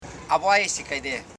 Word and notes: abaiisikaide: a.bai.i.si.kai.de

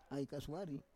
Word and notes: ai kasuari: Roon

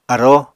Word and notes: aróo: Apatani

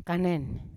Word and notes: kanen: Yawa

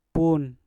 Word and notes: pun: mpur

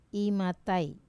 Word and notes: matai: wamesa

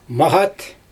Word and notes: maGat: Xyzyl

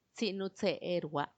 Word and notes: tsinutseerua: Macuiltianguis

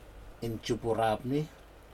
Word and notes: injupurapni: Aneityum

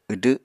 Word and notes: wdw: Apatani

